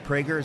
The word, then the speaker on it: Prager: I'm talking to Dennis Prager. I believe it's pronounced Dennis Prager. Is that correct? – Beautifully done.